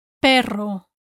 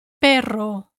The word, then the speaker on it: perro: You may want to practice saying the two syllables on their own. Pe. Ro. Pe. Ro. And now together. Perro. Perro. Make sure the tip of your tongue moves to the right place fast enough.